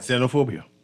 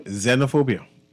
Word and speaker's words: Xenophobia: Xenophobia. – What's it called? – Xenophobia.